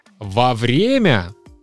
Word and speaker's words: вовремя: Во время. Да, русский язык это просто математика. Первый пример это вовремя. Пишется вместе и ударение на О вовремя.